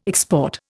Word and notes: export: Indian

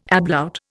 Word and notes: ablaut: Indian

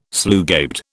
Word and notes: slugabed: British English

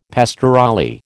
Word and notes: pastorale: American English

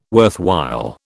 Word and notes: worthwhile: British English

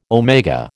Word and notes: omega: American English

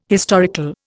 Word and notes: historical: Indian

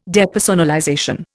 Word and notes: depersonalization: Indian

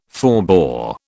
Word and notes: forbore: British English

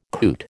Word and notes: coot: American English

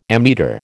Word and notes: ammeter: American English

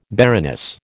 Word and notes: baroness: American English

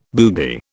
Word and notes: boogie: British English

